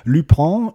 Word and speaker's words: Lupin: der zweite Teil der ersten Staffel von Le Pen. Ne? Ich habe es jetzt wahrscheinlich wieder falsch ausgesprochen, da wird mich meine Frau wieder korrigieren. Lupin, Lupin, also Lupin, schreibt man es auf Deutsch.